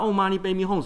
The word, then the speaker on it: Om Mani Padme Hum: 很多人不知道 Om Mani Padme Hum 什么意思。